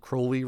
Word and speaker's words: Crowley: Apparently, he pronounced it Crowley, rhymes with holy.